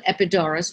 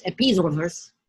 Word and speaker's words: Epidaurus: Because one of the most famous theaters is the theater at, we call it Epidaurus, but it's pronounced Epidaurus.